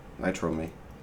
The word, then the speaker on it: Nitrome: Nitrome.